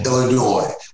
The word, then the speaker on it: Illinois: Illinois, without pronouncing the S. – Without the S.